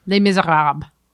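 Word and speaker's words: Les Misérables: If you were to say like the real French version, Les Miserables. – Oh, so there is a B. – But you're not saying the L-E-S at the end.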